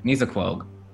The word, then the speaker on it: Nissequogue: Okay. Nisa Nissequogue. – Nisa Quog. There we go.